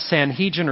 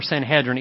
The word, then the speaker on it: Sanhedrin: The Sanhedrin, which by the way, you can pronounce that Sanhedrin or Sanhedrin, either pronunciation's okay.